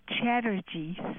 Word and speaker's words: Chatterjee: Okay, my name is Achala, A-C-H-A-L-A. – C-H-A-C-H-A-L-A. – I got that. – Last name is Chatterjee, C-H-A-T-T-E-R-G-A-E-E. Right, like in Chatterbox.